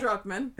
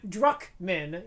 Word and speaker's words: Druckmann: Neil Druckmann. – Druckmann, yes.